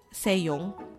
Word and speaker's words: Se-young: So, hindi lang, siguro hindi lang kasi talaga ako fan ni ano, ni nito, ni Lee So-young. Se-young. Tama ba? Mali na naman yung pagbigkas ko. Lee Se-young.